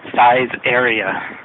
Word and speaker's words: Saizeriya: But the way I remember how to say it is that it sounds a lot like size area.